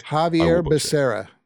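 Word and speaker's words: Xavier Becerra: Javier Becerra.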